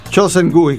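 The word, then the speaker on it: Cho Sen-gui (조승희): Cho Sen-gui, questa è la pronuncia esatta, che ha commesso questa strage.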